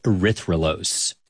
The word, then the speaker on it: erythrulose: And they scanned it for radio signals of different molecules, which is how they found a four-carbon sugar called erythrulose.